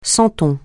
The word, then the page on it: santon: francais